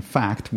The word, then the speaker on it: PHACT: This was published in FACT, which is spelled P-H-A-C-T.